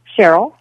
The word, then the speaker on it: Sharyl: I think I've asked them, and they said they just thought it was a nice spelling, but it's just Cheryl, just spelled differently, S-H-A-R-Y-L. – So how do you pronounce it? – Cheryl. – Oh, you do, as if it's an E.